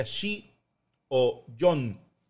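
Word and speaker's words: shi o yon (四): En Japón, el número 4 eh, en japonés se pronuncia shi o yon, porque shi es una palabra que suena muy parecido a la palabra muerte. Y nos tocó en Salamanca,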